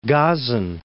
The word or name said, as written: Gazan